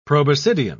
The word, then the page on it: proboscidean: English; British English